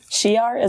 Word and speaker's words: Shi'ar: Shi'ar, is that how you say it? – Yep. – Okay. – You got it.